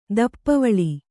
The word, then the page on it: ದಪ್ಪವಳಿ: Kannada